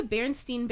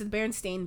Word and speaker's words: Berenstain: It's not the Berenstain Bears. You guys know that, right? It's the Berenstain Bears. That was like one of those Mandela effect because everyone remembered it being Berenstain, but it's not.